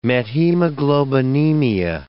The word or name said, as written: methemoglobinemia